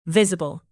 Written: visible

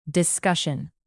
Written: discussion

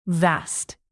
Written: vast

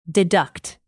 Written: deduct

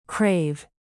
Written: crave

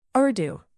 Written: urdu

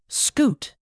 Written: scoot

